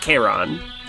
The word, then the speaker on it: Charon: Zoe knows it's pronounced Karen. Charlie is calling him Karen. – I think Solo is going to book it towards Charon.